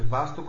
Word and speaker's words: vastu: Imate vastu koji se izgovara sa kratkim a i vaastu sa dugim.